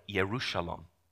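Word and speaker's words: Yerushalom (ירושלים): And some people get really hyper-fixated on the particular location, but Jerusalem is in Hebrew would have been pronounced like Yerushalom.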